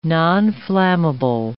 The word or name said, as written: nonflammable